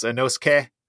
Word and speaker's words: Inosuke: Uh, similarly, something ending in a ske, like a S-U- S-U-K-E, I'll say like inosuke. Incorrect. It's inosuke.